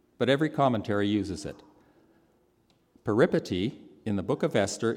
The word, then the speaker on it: peripety: And as I've learned a previous time from this pulpit, for which Frank mercilessly made fun of me, you put the emphasis on the second syllable. Does that make sense? Peripety. Peripety. Peripety is a sudden and unexpected change of fortune.